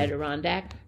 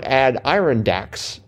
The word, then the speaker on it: Adirondack: Yeah, see, think of A-D as ad. – Ad? – Adirondacks. – How about Adirondacks? – Well, no, I don't think so.